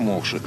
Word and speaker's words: Мохши: Вот этот значок означает как раз Мехэше, вот, и читается как Мохши.